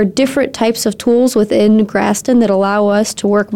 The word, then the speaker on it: Graston: And then one of these other things I see on here is you have experience in Graston technique? Am I saying that right? – You are, yes.